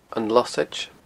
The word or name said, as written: losaid